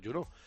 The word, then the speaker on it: Juneau: la capital es Juneau, se dice Juneau, se escribe de una manera que no es ilegible, pero bueno, yo la he puesto Juneau porque se lo digo Juneau.